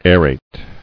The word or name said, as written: Aerating